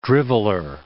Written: driveler

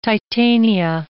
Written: Titania

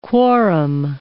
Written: quorum

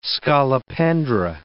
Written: scolopendra

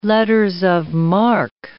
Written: letters of marque

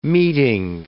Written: meeting

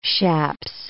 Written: chaps

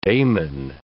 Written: Damon

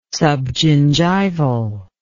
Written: subgingival

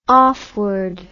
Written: offward

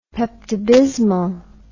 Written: peptobismol

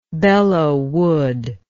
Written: Belleau Wood

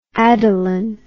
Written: Adaline

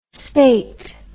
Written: spait

